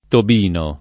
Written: Tobino

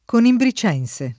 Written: conimbricense

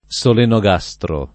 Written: solenogastro

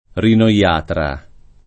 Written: rinoiatra

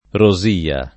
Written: Rosia

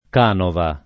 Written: canova

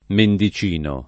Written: Mendicino

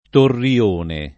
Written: torrione

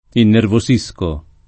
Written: innervosire

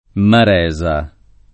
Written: Maresa

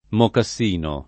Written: mocassino

